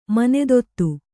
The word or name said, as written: ಮನೆದೊತ್ತು